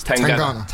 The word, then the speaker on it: Tangana: Tangana.